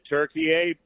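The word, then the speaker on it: Türkiye: The Turks have always called it Turkey A, but in English, the pronunciation was like the bird.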